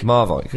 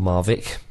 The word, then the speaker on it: Marwijk: Marwijk. Yeah. Or Marvik, depending on if you want to be wrong or not.